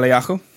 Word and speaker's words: Eliyahu: Eliyahu.